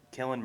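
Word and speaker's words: Cillian: Is it Killen Murphy? Is that how you say it? – Yes.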